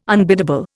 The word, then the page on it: unbiddable: Indian